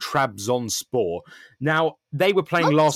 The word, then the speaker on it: Trabzonspor: Well, the thing is, actually, it's kind of phonetic because the name is literally Trabzonspor. But then, you know, when you put...